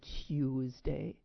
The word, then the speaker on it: Tuesday: I had to go to speech class there, and I was told that the correct pronunciation of that day of the week was Tuesday, like T-Y-U, Tuesday.